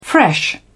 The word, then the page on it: fresh: British English